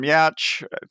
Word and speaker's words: Yauch: So the writers are Adam Yauch.